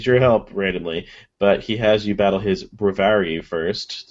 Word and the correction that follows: Braviary: Braviary, okay.